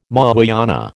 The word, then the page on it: Mahayana: American English